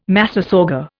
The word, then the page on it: massasauga: Indian